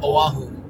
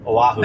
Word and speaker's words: Oahu: How do you say that island? – Oahu. – Oahu. – That's where my wife's from. – Oahu. – Yes.